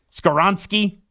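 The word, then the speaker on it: Skoronski: The Northwestern offensive Skoronsky? – Lineman? Skoronsky.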